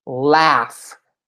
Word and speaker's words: laugh: Olha a pronúncia disso aqui. É F. Laugh. Laugh. Não tem absolutamente nada a ver com a escrita, né?